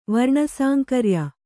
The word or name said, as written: ವರ್ಣಸಾಂಕರ್ಯ